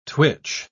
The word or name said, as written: twitch